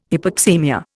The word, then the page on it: hypoxaemia: Indian